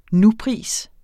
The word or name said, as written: nupris